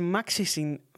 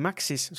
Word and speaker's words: Maxis: No joo, EA julkaisi yhdessä Maxisin, miten tuo lausutaan, Maxis, se on Maxis, mutta Maxisin, Maxisin kanssa SimCity-pelisarjan, kunnes se osti sen vuonna 1997, ja näin syntyi Sims-pelit.